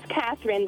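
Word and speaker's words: Cathryn: My name's Catherine, but that's spelled weird as well.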